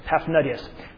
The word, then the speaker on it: Paphnutius: But, say these historians, the law was opposed openly and decidedly by Paphnutius, that's how you say it, Paphnutius,